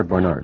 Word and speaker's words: Barnard: Well, Mary Barnard, is it Barnard or Barnard? – I pronounce it Barnard.